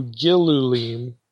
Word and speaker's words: gilulim: And so the other view is that, well, maybe Ezekiel is taking a term for feces, which would normally be pronounced gelalim, and again takes that and alters it to gilulim, maybe again taking the vowels from the word for abominations, the shikutsim, the things that are detestable, in Leviticus.